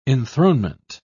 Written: enthronement

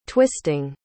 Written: twisting

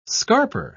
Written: scarper

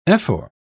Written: ephor

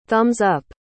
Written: thumbs-up

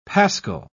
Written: paschal

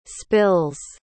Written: spills

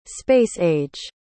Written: space-age

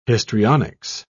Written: histrionics

